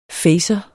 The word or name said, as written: facer